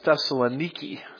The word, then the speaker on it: Thessaloniki: Actually, when I was in Thessalonica, they all talked about it as Thessaloniki, so I can't even get the pronunciation right on this, but it looks to me like Thessalonica, "where there was a synagogue of the Jews."